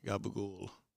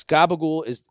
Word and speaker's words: gabagool: But probably I will start with something like gabagool. – Yeah, which is racist, because that's a Neapolitan pronunciation. – Exactly. – I go berserko. Yes, gabagool is closer to Neapolitan, but unless I digress.